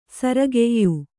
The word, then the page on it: ಸೊರೆಗಾಯ್: soregāy